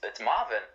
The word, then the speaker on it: Marvin: Yeah, it's more like, yeah, just put the emphasis on the A. And exactly. – On the R. And so you don't really hear the R in the German way. You just say Marvin.